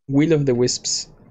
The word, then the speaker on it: Will of the Wisps: Ori and the world of the Wild the Wisp. – ¿Cómo se pronuncia eso? Will of the Wisps.